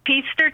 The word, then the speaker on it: Peaster: Peaster, Texas. – Peaster, where is that? – It rhymes with Easter.